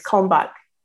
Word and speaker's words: Kalmbach: Kalmbach.